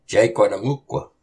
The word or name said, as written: Cáyhqatumoq